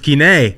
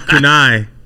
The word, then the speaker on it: Kenai: Just thought you should know that it's pronounced Kenai, not Kunai.